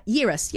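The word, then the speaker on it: Yarros: Rebecca Yeris, I think it is. How do we say it? It's a hard word to say it. Yeris, yep.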